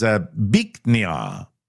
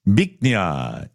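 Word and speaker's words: Bich Nga: It's B-I-C-H. – Okay. – N-G-A. The correct pronunciation is Biknia. Biknia. And that loosely translates into beautiful jade. But obviously, the way it's spelled, B-I-C-H space N-G-A, looks amusing in English to some people.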